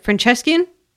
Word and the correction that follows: Franciscan: Sure. – I seriously meant to look that up before doing this, and then I just never did. – Franciscan. – Okay, can I do that over? – Like San Franciscan.